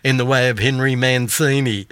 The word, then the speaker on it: Mancini: I came across a street named Via Henry Mancini, pronounced Mancini, in a small town called Scano in Abruzzo, Italy.